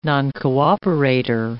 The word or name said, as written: noncooperator